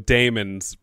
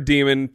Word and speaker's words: daemons: Lyra's demon, which, by the way, for the season one, I kept calling them daemons because there's an A in the word. – Mm-hmm. And the world has been like, hey, it, the A is silent.